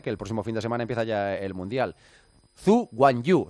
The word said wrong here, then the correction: Zhou Guanyu: ¿se dice así, Carmichael? Zhu Guanyu. – Es Zhou, Guanyu Zhou. Es como se dice. – Zhu Guanyu Zhou. – Es Zhou Guanyu. Es Zhou Guanyu. Es así.